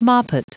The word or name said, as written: moppet